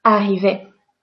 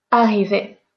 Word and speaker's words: arriver: If there is one R, two R, 10 R, 1000 R, we are all going to pronounce it the same, as if there was just one R. So for example, to arrive. You have to write it with two R, right? Arriver. Arriver. It doesn't matter.